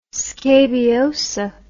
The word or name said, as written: scabiosa